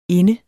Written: inde

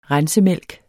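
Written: rensemælk

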